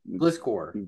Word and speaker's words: Gliscor: The big Gliscor.